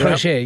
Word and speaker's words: Crochet: The Sox, Bill, they draft pitcher Garrett Crochet. I hope I'm saying that right. – Crochet, yes.